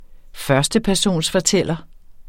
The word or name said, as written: førstepersonsfortæller